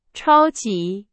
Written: 超级

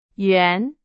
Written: 圆

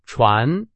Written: 传

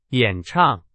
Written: 演唱

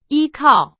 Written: 依靠

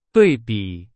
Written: 对比

